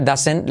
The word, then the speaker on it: doesn't: He, semiclave H, uh, Dasen, la T de doesn't no se pronuncia, doesn't, left y como E,